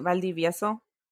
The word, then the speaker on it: Valdivieso: Valdivieso.